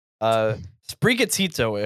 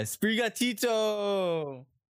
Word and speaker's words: Sprigatito: Sprigatito, I heard, is how it's pronounced. – Yeah, Sprigatito.